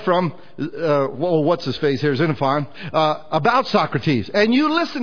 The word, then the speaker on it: Xenophon: There was another man called Xenophon. Starts with an X. You pronounce it Xenophon or Xenophon.